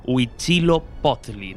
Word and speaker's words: Huitzilopochtli: Huitzilopochtli.